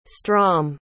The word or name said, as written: strom